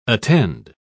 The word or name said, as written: attend